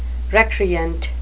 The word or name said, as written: recreant